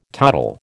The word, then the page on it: toddle: American English